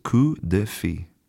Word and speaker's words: coup de fil: It's spelled C-O-U-P space D-E space F-I-L. Now, I haven't studied French since high school, so maybe my pronunciation in general is not great here, but I believe that it's coup de fi.